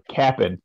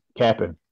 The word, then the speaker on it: Cap'n: I am eating Captain. Oh, I'm sorry. I'm sorry. Cap'n. – Cap'n. Yeah, say it right.